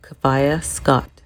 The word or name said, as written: Kafayat Scott